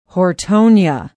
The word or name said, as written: hortonia